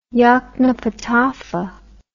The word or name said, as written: yoknapatawpha